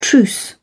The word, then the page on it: truce: British English